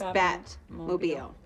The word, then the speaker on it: Batmobile: Batmanmobile. – Batmobile. – That's what I said, Batmanmobile. – You're putting the there's no man, it's just Batmobile. – Batmanmobile. – Batmobile.